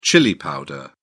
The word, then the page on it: chili powder: British English